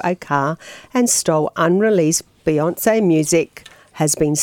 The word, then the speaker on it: Beyonce: Beyonce.